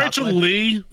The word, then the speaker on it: Rachel Lee: What's Rachel Lay? – It's Rachel Lee. Thank you. – Rachel Lee cosplay. Mm-hmm. I keep saying Rachel Lay cosplay. My bad. It just sounds like it rhymes better.